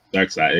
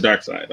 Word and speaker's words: Darkseid: Am I saying that right? – Darkseid. – It is Darkseid. Okay. – Yeah. Just spelled goofy.